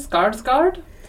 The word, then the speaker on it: Skarsgård: Um, and then we have Bill, um, played by Stellan Skarsgård.